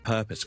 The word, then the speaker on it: purpose: 咁我哋 purpose 嘅 per 咧就系 per，呢个大部分应该冇问题啦。